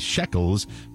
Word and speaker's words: Shekel: October 4th was my stepson Hunter's shekels, pronounced like the currency shekel.